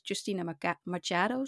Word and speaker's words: Justina Machado: En de hoofdrolspeler is Justina Machado, zo spreek je dat uit.